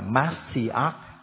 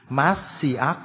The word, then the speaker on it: Mashiach: Còn tiếng chúng tiếng Christos, tiếng Do Thái thì đọc là Mashiach. Mashiach, nhưng mà dịch ra tiếng Anh, tiếng Pháp thì người ta nhất là Việt Nam gọi là Messiah.